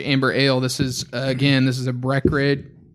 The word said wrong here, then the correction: Breckenridge: You just take out those middle syllables and you're fine. Breckenridge Brewery.